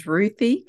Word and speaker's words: Ruthi: Well, my name is Ruthi Cohen Joyner.